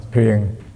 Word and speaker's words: thiền: Trong quá khứ thì người ta dịch những vị thiền sư đó, người ta còn dịch là thiền sư nữa. Một chữ thôi, nhưng mà miền Bắc họ đọc là thuyền. Chữ này đây, Bắc đọc là thuyền, còn Nam đọc là thiền.